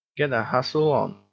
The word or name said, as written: get a hustle on